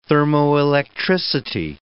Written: thermoelectricity

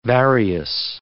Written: various

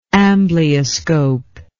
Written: amblyoscope

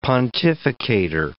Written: pontificator

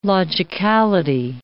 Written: logicality

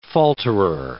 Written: falterer

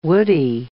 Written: woody

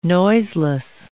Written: noiseless

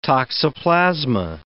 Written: toxoplasma

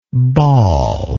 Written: bawler

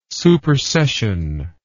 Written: supersession

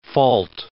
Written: fault

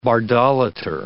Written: bardolater

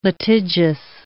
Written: litigious

